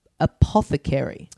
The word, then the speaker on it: apothecary: They also introduced two more characters who are coming in, a merchant character and an apothecary. – Yes. – Is that how you say it? – It certainly is.